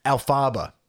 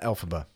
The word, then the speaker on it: Elphaba: Oh, no, yeah, Alphaba, not Elphaba.